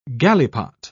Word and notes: galipot: British English

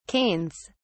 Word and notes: canes: British English

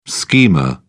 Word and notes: schema: British English

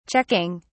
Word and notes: checking: British English

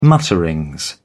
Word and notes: mutterings: British English

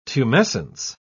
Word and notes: tumescence: British English

